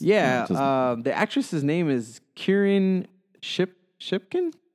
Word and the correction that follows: Shipka: Shipka? – I'm pronouncing that name very wrong.